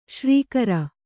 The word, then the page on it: ಶರ್ಕರೆ: śarkare